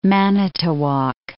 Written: Manitowoc

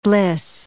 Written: bliss

